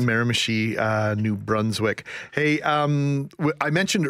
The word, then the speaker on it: Miramichi: That the federal government has been dealing with, where I said Miramichi, New Brunswick, and I've been told it's Miramichi.